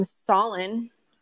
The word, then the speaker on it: Solon: JB, Jared from Solon. That's Solon. – Solon rhymes with stolen.